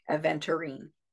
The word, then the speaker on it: aventurine: So that is A-V-E-N. T-U-R-I-N-E, I think, aventurine. So, or aventurine, some people pronounce it.